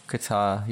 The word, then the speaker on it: Qatar: Qatar. – Qatar, yeah.